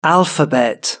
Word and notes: alphabet: British English

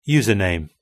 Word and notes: username: British English

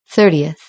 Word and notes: thirtieth: British English

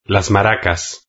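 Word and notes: las maracas: Spanish (Spain)

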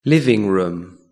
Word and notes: living room: British English

